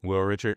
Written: Will Richard

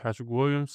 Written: Patrick Williams

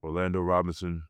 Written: orlando ROBINSON

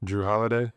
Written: Jrue Holiday

